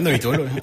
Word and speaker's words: Neutol: C'est comme ça, ça se prononce bien, je le prononce bien ou... – À peu près. – À peu près, vas-y. – Non, bah, c'est bien, Neutol.